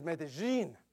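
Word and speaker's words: Medellín: I kept saying Medellin. You know, next thing you know, it's Medellin. Now it's Medellin. Is it the last one, Evelyn? Okay. Finally got it right. Medellin.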